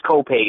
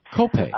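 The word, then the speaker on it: Copiague: How do you pronounce that city in New York? – It's Copaig. It's an Indian name. – Copaig.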